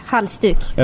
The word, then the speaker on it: halsduk: Halsduk.